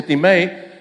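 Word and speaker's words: timei (τιμή): Ahora, honor es la palabra timei, así se pronuncia timei en griego, y se traduce como para valorar, respetar, tener en alto estima, tratar como algo precioso, algo que tiene valor.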